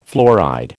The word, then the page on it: fluoride: American English